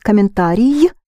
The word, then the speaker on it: комментарий: A comment is комментарий with a short e in the end. Комментарий. Think commentary.